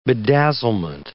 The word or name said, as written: bedazzlement